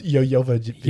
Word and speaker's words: Jovovich: Daniel Day Kim, Mila Jovovich. – Yep. – It's pronounced Yo-Yo-Vich.